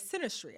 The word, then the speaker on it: synastry: So I'd love to transit funny pun, into our last section of this episode, which is synastry. I'm saying that correctly, right? Synastry? Yeah. Okay, amazing.